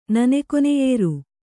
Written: ನನೆಕೊನೆಯೇರು

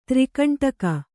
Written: ತ್ರಿಕಂಟಕ

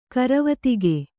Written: ಕರವತಿಗೆ

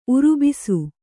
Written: ಉರುಬಿಸು

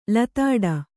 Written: ಲತಾಡ

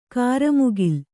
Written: ಕ್ರಿಮಿಕುಲ

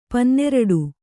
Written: ಪನ್ನೆರಡು

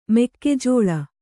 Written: ಮೆಕ್ಕೆಜೋಳ

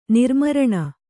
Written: ನಿರ್ಮರಣ

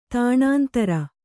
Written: ತಾಣಾಂತರ

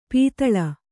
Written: ಪಾತಾಳಿ

